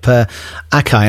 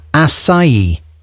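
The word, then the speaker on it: açaí: So I've just looked up Acai and I have said it completely wrong. It's acai. Acai. Really? I thought it was acai. Anyway, it's a small dark purple fruit, which is a superfood. So there is an irony that I've just mispronounced the most mispronounced word. Acai. Does anyone say it like that? Surely not.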